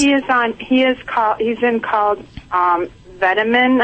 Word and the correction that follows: Vetmedin: Okay, Vetmedin.